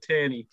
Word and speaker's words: Tierney: Even the keepers, Craig Gordon, David Marshall, John McLaughlin, Scott McKenna, Kieran Tyrini, Declan Gallagher. – Did you say Tyrini? – How do you say it? Tierney. – Tierney. KT.